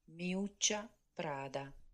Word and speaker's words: Miuccia Prada: Miuccia Prada.